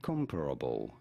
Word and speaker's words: Comparable: Comparable. You do want to stress on the first syllable, the com syllable.